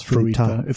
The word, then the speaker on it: Fruita: which would later be called Fruita because of all of the... – Fruta because of all of the fruit trees. None of the towns... – You're adding extra vowels. Fruita. – Fruita.